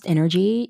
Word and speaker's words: Nrgy: Uh, so it's pronounced energy, uh, but it's spelled N R G Y. N R G Y, and that is the spelling of my real name, and you do pronounce it Nrgy.